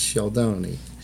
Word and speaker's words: Scialdone: SCI is shh, and then E is silent, right? Like Capone and Stallone, but in Italian, it's Sheldoni.